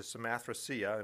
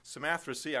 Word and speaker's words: Samothracia: We came with a straight course to Samothracia, and there's several different ways to pronounce that. There are some, and it's often pronounced with a K sound in some different readings. They have the K sound in there.